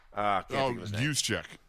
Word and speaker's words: Juszczyk: Starts with a J, but it's not pronounced like that. And San Francisco. – Juszczyk.